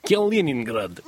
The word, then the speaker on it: Kaliningrad: Uh, the Russians have announced they're going to halt plans uh, to deploy short range missiles in uh, uh, near Kaliningrad, their Baltic enclave. You can pronounce it better than that. You just went straight through Kaliningrad there. Kaliningrad. There you go.